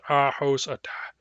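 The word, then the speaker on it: Ahousaht: So, for example, the Ahauzit tribe is called is its name is pronounced like I just said it in English, Ahauzit, Ahauzit. But its Nuchanoth pronunciation is Ahosata, Ahosata.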